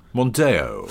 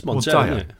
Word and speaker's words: Mondeo: And, how do you say Mondeo? – So, Mondeo. – Fold. – Fold. – Mondeo. It's Mondeo. Mondeo. – Deo. Yeah, Deo. Deo. – It's Deo.